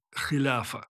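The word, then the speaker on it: хиляфа (خلافة): Ключевой термин, арабский термин «халифат» в арабском оригинале произношении «хиляфа» – это сам институт власти халифа.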